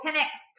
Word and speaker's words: Penix: But as Tyler said, Quarterback Michael Penix tore his ACL.